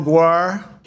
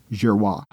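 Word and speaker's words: Giroir: Brett Girois, or as Donald Trump calls him, Admiral Guar. – Here was Admiral Girois, pronounced Girois.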